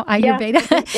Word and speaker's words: Ayurveda: Am I saying it right now? Ayurveda? – Yeah.